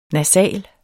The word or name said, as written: nasal